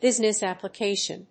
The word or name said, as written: business application